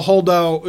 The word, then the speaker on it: Holdo: Holdo. – Holdo.